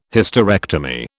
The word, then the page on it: Hysterectomy: British English